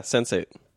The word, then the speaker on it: Sense8: Well, it's supposed to be like pronounced Sense8. Isn't it? – Yeah, sensate. Because it's like sensation.